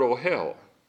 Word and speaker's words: ohel: Because the Hebrew word for tent is the word ohel. Yeah, it's an O, eh. So it's an O, full long O, and hell is, I'm trying to keep from using the Hebrew alphabet.